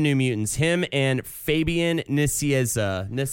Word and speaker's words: Nicieza: At some point, it devolved to Nijiju, but it's Nicieza.